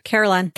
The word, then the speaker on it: Carillon: Carillon.